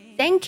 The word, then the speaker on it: thank: 首先呢，thank 这个单词以咬舌音起音，那么发音的时候呢，要轻轻地咬住舌尖出气，thank you，而不能发成摩擦音，thank you 和 s 是非常不一样的两个音。